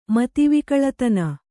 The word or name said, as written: ಮತಿವಿಕಳತನ